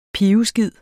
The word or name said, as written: PIVESKID